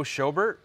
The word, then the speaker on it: Schobert: Joe Schobert.